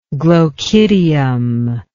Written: glochidial

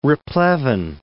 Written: replevin

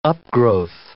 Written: upgrowth

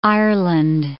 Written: Ireland

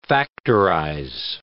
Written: factorize

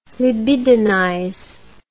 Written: libidinize

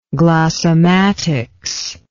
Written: glossematic

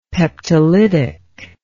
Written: peptolytic

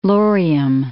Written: Laurium

